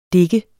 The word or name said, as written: dik